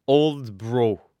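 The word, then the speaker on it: Aldeburgh: On dit Aldebourg. Repeat after me.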